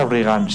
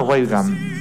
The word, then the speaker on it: Reagan: Y otra cosa, no se pronuncia Reagan, se pronuncia Reagan.